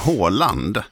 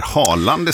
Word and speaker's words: Haaland: Fotbollsspelaren, alltså norska, att folk till höger och vänster säger Haaland istället, därför att så uttalar ju engelsmännen det, till exempel när man hör referat och sådär, men 2 A är ju Å.